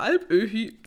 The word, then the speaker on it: Alpöhi: Großvater Alpöhü tut. Öhü? Öhü. Großvater Alpöhi.